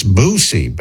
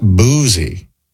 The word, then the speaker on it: Boosie: It's Boosie Badass, not Boozy. And Tony says the same thing. Boosie, not Boozy.